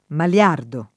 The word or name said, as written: maliardo